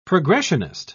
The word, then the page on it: progressionist: British English